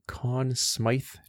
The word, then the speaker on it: Conn Smythe: the Conn Smythe Trophy.